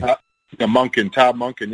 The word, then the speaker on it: Todd Monken: Todd Monken, yes.